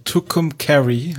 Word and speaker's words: Tucumcari: Tucumcari heißt es? – Tucumcari, ja.